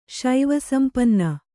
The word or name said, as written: ಶೈವಸಂಪನ್ನ